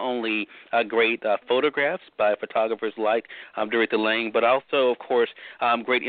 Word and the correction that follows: Dorothea Lange: Dorothea Lange.